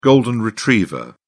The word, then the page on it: golden retriever: British English